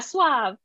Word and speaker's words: suave: And so when um, they talk about like kind of like the I always mispronounce the word. Is it suave? Like that kind of a yeah, suave.